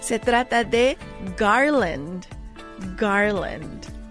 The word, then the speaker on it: garland: Así que eso es un garland. Garland se escribe G-A-R-L-A-N-D. G-A-R-L-A-N-D.